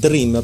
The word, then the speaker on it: Drim: però scritta proprio, diciamo, italianizzata, ecco, mettiamola così, Dream, proprio come si pronuncia, giusto Lorenzo? – Giusto, giusto, scritta proprio D-R-I-M se non sbaglio.